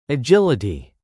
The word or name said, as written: agility